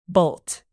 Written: bolt

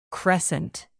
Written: crescent

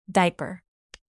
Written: diaper

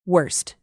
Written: worst

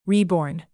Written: reborn

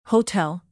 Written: hotel

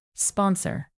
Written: sponsor

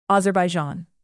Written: azerbaijan

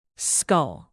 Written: scull

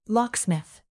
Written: locksmith

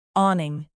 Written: awning

